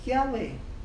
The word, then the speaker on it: Yahweh: At pinopronounce to as Yahweh.